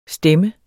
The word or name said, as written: stem